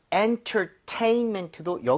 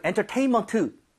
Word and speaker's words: entertainment: 자, entertainment도 여기 강세를 줘서 여러분 entertainment 이게 아니라 네.